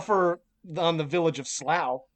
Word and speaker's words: Slough: You're not just pronouncing slow as slough, it's actually slough.